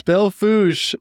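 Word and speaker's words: Belle Fourche: Belfouche, not spelled that way, but that's how you say it.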